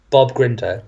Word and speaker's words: Grindr: Now, you said that you used an app called Grindr. Is that, am I pronouncing it correctly? – Yes, it's Bob Grindr.